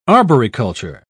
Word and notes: arboriculture: British English

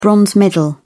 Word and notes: bronze medal: British English

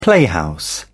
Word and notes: playhouse: British English

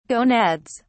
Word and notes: gonads: British English

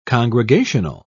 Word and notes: congregational: British English

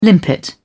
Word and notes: limpet: British English

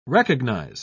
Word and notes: recognise: British English

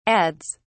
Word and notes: adds: British English

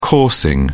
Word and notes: coursing: British English

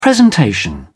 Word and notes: presentation: British English

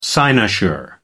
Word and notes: cynosure: American English

